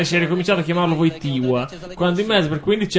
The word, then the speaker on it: Wojtyła: Lo riconosco perché c'è proprio quella L, che è la stessa L che trovavi nella parola Wojtyła e che ti costringeva a pronunciarlo Wojtyła.